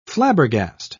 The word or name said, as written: flabbergast